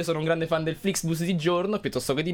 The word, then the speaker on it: Flixbus: Stavamo parlando di Flixbus, però questa volta l'ho detto giusto.